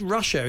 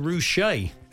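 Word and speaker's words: Ruscha: He said, Ed Rusher is pronounced Rouché.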